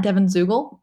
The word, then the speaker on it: Devon Zuegel: My Twitter handle is at Devon Zuegel.